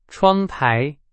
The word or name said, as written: 窗台